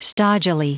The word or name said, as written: stodgily